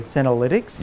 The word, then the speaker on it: senolytics: Senolytics. Am I saying that correctly? – Senolytics? – Yes. – Yeah.